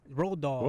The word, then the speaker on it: Roald Dahl: Roald Dahl.